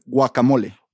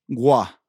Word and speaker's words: guacamole: So the correct way to say it is guacamole. – Guacamole. You're not say the first, just the first letter, pronounce that first, that G. – Gua. – Gua. Gua. Guacamole. – Correct.